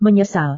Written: menyesal

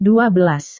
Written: dua belas

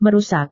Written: merusak

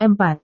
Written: empat

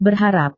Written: berharap

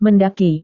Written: mendaki

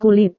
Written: kulit

